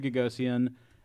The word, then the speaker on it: Gagosian: Am I pronouncing his name right? – Yes. – Okay, cool. So Larry Gagosian has a string of very high-profile galleries, New York, Athens, London, etc.